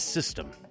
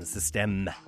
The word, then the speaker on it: Système: This release was Boogeyman EP from March this year, and this track is System, but to pronounce it like a French person, Système, with an E at the end.